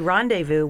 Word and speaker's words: rendezvous: All right, Scott, your word is rendezvous. Rendezvous is a noun, a meeting at an appointed place and time. The astronauts completed a rendezvous with the orbiting space station. – R-E-N. And the last. Okay. R-E-N-D.